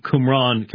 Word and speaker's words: Qumran: And it came up in the interview, the discussion of the Qumran community, that's how it's pronounced, and the idea of there being, you know, just determined by Stoic deterministic philosophy and things like this.